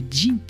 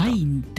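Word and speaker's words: zine: ずっとさこれジンって読むの知らんかってさ、うん、なんて読んだザインって読んでた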